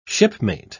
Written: shipmate

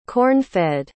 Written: corn-fed

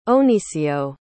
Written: ohnicio

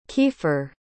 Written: keifer

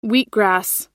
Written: wheatgrass

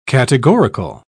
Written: categorical

